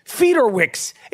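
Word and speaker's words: Fiedorowicz: I told you to get the Polish beast, Fiorokwicz. I don't even know how to say his name. Fiorokwicz. How do you say this dude's name? Fiedorwicz. It's so Polish you can't even pronounce it. Fiedorwicz.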